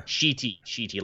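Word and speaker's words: Schiti: Sheety, like Ali Sheety.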